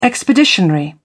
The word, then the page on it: expeditionary: British English